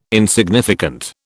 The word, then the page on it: insignificant: British English